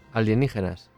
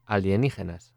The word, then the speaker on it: alienígenas: Alienígenas. – Extraterrestrial. – Exactly. – Aliens. Could say it again. – Alienígenas.